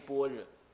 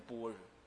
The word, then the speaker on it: 般若: 他就非得造一个新词，叫做般若。那般若现在人不会念吗？念什么？念般若，是不是这样的？嗯，那就是为什么我们要特别念般若？很奇怪啊，那就唐朝的古音有这个音，懂我意思吧？有这个音念成般若。